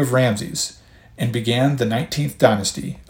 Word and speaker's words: Ramses: I will pronounce the name in this episode as Ramses, though contemporary scholars now tend to spell it as Ramesses, R-A-M-E-S-S-E-S. The name, however you say it, refers to praise to the sun god Ra, who had resumed some popularity in the post-Akhenaten period.